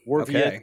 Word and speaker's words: Borowiecki: That's coming directly from Mark Borowiecki's mouth. – Yes. – So there may be others out there who want to interpret it differently and say, oh, no, this is how you say it. But the man himself told me, Mark Borowiecki.